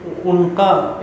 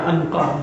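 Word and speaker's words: عنقا: دیکھیں انقا یا انقا دو تلفظ ہیں اس کے، انقا نامی پرندے کو آپ نے دیکھا ہے کبھی یا اس کا کوئی وجود کسی نے دیکھا؟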